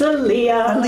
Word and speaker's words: Aaliyah: I hope I pronounced this right. Aaliyah? – It's Aaliyah. Aaliyah. But you were close enough.